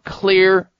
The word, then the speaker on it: Xlear: then be thinking about clear nasal sprays. Clear is spelled with an X, however, X-L-E-A-R.